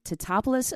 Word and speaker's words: Teutopolis: So you are from Teutopolis, Illinois, correct? – Yes. And I'm so impressed that like, right off the bat, you pronounced it right.